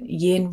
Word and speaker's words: yin: So I always remember that yin rhymes with dim.